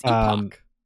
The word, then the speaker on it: epoch: Epic? – Sorry, however many thousand years. – I don't know why both of them say epic. – It's epoch. That's how the word's pronounced.